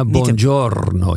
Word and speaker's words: Buongiorno: Buongiorno. Zeg ik het goed? – Bijna. Buongiorno. Je buongiorno. Moet de rollende R.